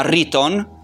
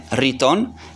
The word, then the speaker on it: rhyton: in greco si chiama riton, si scrive R-H-Y-T-O-N, riton,